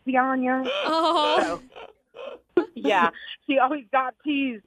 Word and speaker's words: Zyanya: Her name's Zyanya. – How do you spell that? – It's Z-Y-A-N-Y-A.